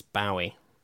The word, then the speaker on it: Bowie: We were saying Bowie, and I even asked you, and you were like, it's Bowie. But that was wrong. – That's wrong? – Yeah, it's Bowie. – It's Bowie. – It's like, as in Zoe.